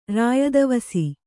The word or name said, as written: ರಾಯದವಸಿ